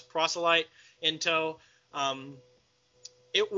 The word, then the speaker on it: proselyte: When, quote, Squishy made his first appearance, when in that splash with Sinestro, I remember the guys saying, Prosolite, it is proselyte.